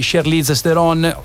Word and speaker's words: Charlize Theron: Venite a vedere questo film dal cast stellare guidato da Matt Damon, di Charlize Theron.